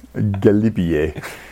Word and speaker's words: Galibier: Galibier. – Galibier, okay. Right, fine, yeah, Galibier.